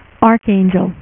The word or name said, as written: Archangel